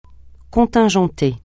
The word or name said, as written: contingenter